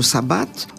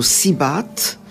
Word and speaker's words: صابات: مثلا في تونس يقولوا صابات، وبعض المدن يقولوا سيبات وبرتال وسبط.